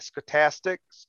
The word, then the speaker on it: Stochastics: Stochastics. – Because it's S-C-O-H, right? – S-T-O-C-H. – Stoke. Almost like stoic.